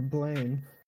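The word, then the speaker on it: Blame!: And is it Blam or Blame? – It's pronounced Blam.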